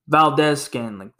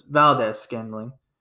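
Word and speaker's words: Valdes-Scantling: I think that's how to pronounce his name. Valdez Scantling. That's what it is. Valdez Scantling.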